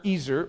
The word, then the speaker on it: ezer: Now I'm going to swap out the word helpmeet with the Hebrew word, which is ezer. Okay, it looks like Ezer or Ezer, but it's actually pronounced Ezer.